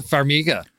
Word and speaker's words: Farmiga: And even I think somewhat unrecognizable as Vera, how do you pronounce Farmiga. – Farmiga.